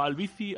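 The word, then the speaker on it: Albicy: Albici, Albici. Como el DJ, ¿no? Albici.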